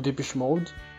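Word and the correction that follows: Depeche Mode: Depeche Mode.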